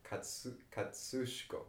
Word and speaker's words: Kosciuszko: Well, actually, I don't know this, but I know how it's pronounced, because my Russian friend who drove us there, she taught us that Kosciuszko was actually pronounced, the first two syllables is like katsu, like katsu. Like a katsu chicken, right? Katsushko or something like that.